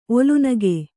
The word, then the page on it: ಒಲುನಗೆ: olunage